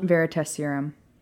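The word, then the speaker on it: Veritaserum: And then they decided to mix their fire whiskey with the, how do you say it, the Veritas serum? Is that what it's called? The Veritas serum.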